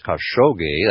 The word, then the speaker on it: Khashoggi: You can type it in to the browser, your favorite browser, Adnan, it's A-D-N-A-N, and the last name is Khashoggi, that's how it sounds phonetically, but it's K-H-A-S-H-O-G-G-I. That's K-H-A-S-H-O-G-G-I.